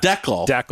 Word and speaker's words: decal: I think this is a Western Canada thing, and I looked it up, and it is D-E-C-A-L. How do you pronounce that? – Deckel. – Thank fucking no, it's, fucking thank you. – It's not you. It's Americans.